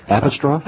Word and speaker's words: Apostrophe: And I, Apostrophe? – Apostrophe, yeah. They don't say apostrophe, but it's spelled like apostrophe.